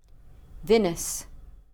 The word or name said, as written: vinous